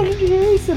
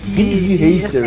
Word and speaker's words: Ridge Racer: Cara, eu acho que é Rid Racer, velho. É. Acho que é Rid Racer. – Rid Racer, assim, né? – Que se escreve. – É, Ridge de ponte, né? De bridge, só que com E.